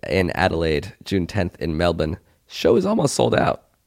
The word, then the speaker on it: Melbourne: We just booked an Airbnb in Melbourne. – There we go. Oh, so way to pronounce it correctly. – Huh? – Way to pronounce that correctly. – What do you mean? – Melbourne.